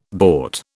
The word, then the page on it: bort: British English